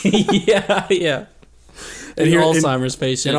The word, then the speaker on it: Alzheimer's: I can never say that right. I said Alzheimer's, I think. – Yeah, you did. It's fine.